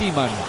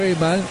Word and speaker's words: Emam: Es difícil la pronunciación, porque para mí es Iman. Pero lo escucho Eman, Ashur, para mí es Ashur, pero más o menos nos vamos a estar entendiendo en cómo vamos a pronunciarlo al 8.